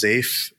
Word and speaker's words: Zaif: And we've been seeing some interesting behavior from both, I believe it's pronounced ZAIF, Z-A-I-F, and Cryptopia, who are based out of New Zealand.